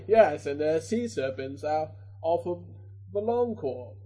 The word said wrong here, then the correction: Volantis: I said that wrong. – Volantis. – Volantis, thank you.